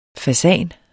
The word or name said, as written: fasan